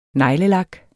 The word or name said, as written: neglelak